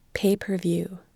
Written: pay per view

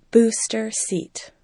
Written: booster seat

